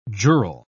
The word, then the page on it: jural: British English